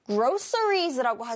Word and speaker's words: groceries: groceries라고 하지 않고요. groceries 이렇게 합니다. groceries 좀 희한한 발음이죠.